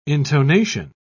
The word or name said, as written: intonation